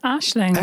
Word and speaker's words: Aisling: Do I pronounce it correct, Ashling, or is it Aisling? – It's Ashling.